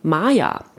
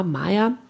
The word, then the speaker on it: Mya: Es ist ähm, Maya, wird sie, glaube ich, ausgesprochen. Mia geschrieben, M-Y-A, Maya.